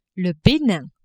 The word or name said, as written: Benin